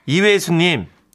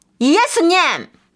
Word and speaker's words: 이외수: 아직도 우리 엄마는 소설가 이외수님을 이렇게 발음하세요. – 뭐? – 예수님! – 이외수님. 이예수님! 예수님.